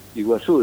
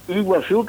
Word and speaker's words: Iguazú: Por ejemplo, dicen Iguazú, dice. Y en realidad no es Iguazú. La Y se pronuncia U. Iguazú, que significa agua grande.